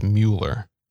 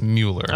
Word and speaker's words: Mueller: I'm going to say it's not pronounced like the detective. So it's Mueller. – Okay. – I believe it's Mueller.